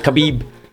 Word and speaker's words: Khabib: Did I say his name right? – Yeah. Khabib.